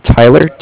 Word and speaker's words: Tyler: My name is Tyler, T-Y-L-E-R. – Okay. – And I'm calling from Bank of Cardiff. It's spelled C-A-R-D-I-F-F, as in Frank Frank.